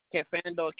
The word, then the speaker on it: Kafando: C-H-E-I-C-K. – And your last name? – Kafando, K-A-F-A-N-D-O.